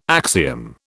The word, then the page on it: axiom: British English